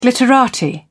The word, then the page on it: glitterati: British English